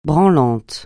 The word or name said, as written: branlant